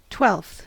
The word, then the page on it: twelfth: American English